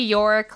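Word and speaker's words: Iorek: Because is his name actually Yorick? Like, alas, poor Yorick. – It is, but it's spelled slightly differently because it's, Icelandic. – It starts with an I, I think. – Oh, okay. Well, at least I'm saying it right.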